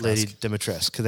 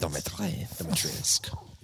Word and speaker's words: Dimitrescu: Lady Dimitrescu. – Lady Dimitrescu. Thank you. Welcome. I can't pronounce words. – Dimitrescu.